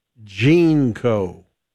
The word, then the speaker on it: JNCO: The JNCO were pronounced Jeanco.